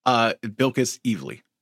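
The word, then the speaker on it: Bilquis Evely: And like, shout out to I always mispronounce the artist's name. What is it? – Bilkis Evely is how Bilkis. I've always said it.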